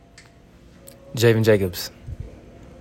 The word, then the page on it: Javen Jacobs: JAY vihn JAY cubs